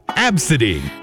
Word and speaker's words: Abcde: And then the name spelled A-B-C-D-E. Apparently it's pronounced Absidy.